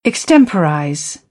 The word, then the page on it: extemporize: British English; əɹ